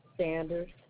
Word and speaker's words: Sanders: T-A-M-I-A. – T. – T-A-M-I-A. – First, middle initial. – M. – Last name. – Sanders. – S. – S-A-N-D-E-R-S.